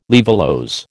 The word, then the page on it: laevulose: American English